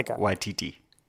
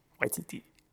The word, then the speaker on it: Waititi: Waititi. – Waititi.